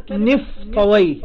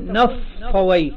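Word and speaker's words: نفطويه: كلمة نفطوي يجوز أن تنطق هكذا بكسر النون، أو تقول نفطوي، نفطوي.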